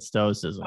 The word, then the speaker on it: stoicism: Is that how you pronounce that? – Yeah, that's it, stoicism.